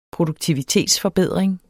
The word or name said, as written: produktivitetsforbedring